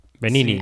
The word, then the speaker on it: Benigni: Benigni o Benigni, no sé muy bien cómo se dice en, en italiano, nunca sé dónde poner la ñ. ¿Cómo se dice, Janina? – Yo creo que es Benigni. – Benini. Así Benini, yo creo. Así como se oye.